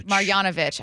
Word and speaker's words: Marjanovic: Marjanovic.